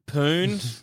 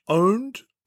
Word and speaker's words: pwned: Pooned? – Don't you mean bone? – It's P-N-P-00-D. – Actually, you'll find that pwned is actually pronounced owned.